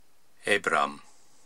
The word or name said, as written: Aibram